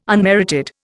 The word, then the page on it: unmerited: Indian